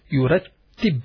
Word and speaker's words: يرتب: يا را تا and ba, and there is a shadda on top of the ta and a kasra, small dash. يرتب. So you stress more on the ta while you are pronouncing it.